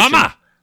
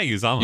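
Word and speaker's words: Uzomah: I feel like one we could talk about is CJ Uzoma, if that's the correct pronunciation. – Uzoma! – Is that right? – Yeah, Uzoma.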